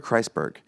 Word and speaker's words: Kreisberg: Sorry, I'm just mispronouncing his name. Andrew Kreisberg. – You got it right earlier.